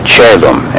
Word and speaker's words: caelum: So whether it's spelled on the page C-A-E-L-U-M or C-O-E-L-U-M, it is still pronounced chelum, as if the vowel cluster were a simple E.